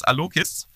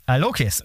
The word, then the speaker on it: Alloquiz: Also vielleicht die erste Frage an dich, wie spricht sich der Name aus? Alloquiz? Und hallo Kies. Wie spricht man es aus? Erstmal willkommen, schön, dass du dabei bist. – Dann löse ich als erstes mal das Rätsel auf. Wir nennen uns Alloquiz.